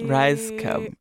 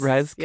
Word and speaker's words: Rajskub: Rajskub. Oh, am I saying it right? – You. Yes. – Rajskub. – Yes.